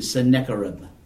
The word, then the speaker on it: Sennacherib: One is Sargon, S-A-R-G-O-N. And after Sargon, then we have King Sennacherib. And that is spelled S-E-N-N-A, Senna, and then Kerib, C-H-E-R-I-B, Sennacherib. Okay.